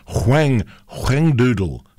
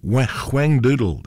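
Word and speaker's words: Whangdoodle: Huang doodle, spelled W-H-A-N-G, and then doodle, D-O-O-D-L-E. Huang doodle, they pronounced it. Huang.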